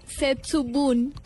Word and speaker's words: Setsubun: ¿Setsuboom? – Murcia, por favor. Set con T, Setsubun.